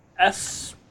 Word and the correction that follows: Espgaluda: Espgaluda. Espgaluda.